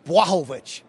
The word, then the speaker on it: Blachowicz: UFC 239, and I'm going to pronounce this correctly, Jon Blachowicz.